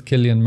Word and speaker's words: Cillian: But it's always pronounced Killian if it's Irish. – Yeah. Yeah. – Well, I don't see it anywhere else with that name. – It's just the C. – Aye. – Throws everyone.